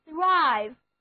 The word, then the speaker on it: thrive: 好了，现在再回到 prosper 上来，我们知道它另外的一个近义词是 thrive， T-H-R-I-V-E， thrive，字母组合 T-H 发作的轻咬舌音，单词就读作 thrive，表示兴旺、繁荣，是一个动词。例如 A business cannot thrive without investment.